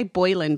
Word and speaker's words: Boleyn: B-O-L-E-Y-N. Because I always want to say Boleyn, but it's not. It's more Boleyn.